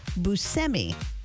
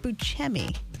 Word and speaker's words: Buscemi: In a new profile in GQ magazine, the writer says that Steve pronounces it Buscemi, not Buscemi.